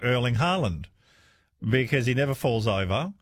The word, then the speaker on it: Erling Haaland: Erling Haaland is pronounced in Norwegian like, it's Erling Haaland.